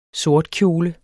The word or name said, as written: sortkjole